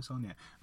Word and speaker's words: Saulnier: So the guy who made this movie is Jeremy Saulnier, and I've heard his name pronounced differently, but I'm pretty sure it's Jeremy Saulnier.